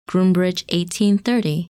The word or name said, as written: Grb 1830